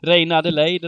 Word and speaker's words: Reine Adelaide: Nu har ju både, ja, hur säger man? Reine. Reine Adelaide, eller vad uttalar man det? – Ja, Reine Adelaide, precis.